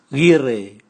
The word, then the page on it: gire: Raga